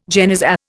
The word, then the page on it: genizah: Indian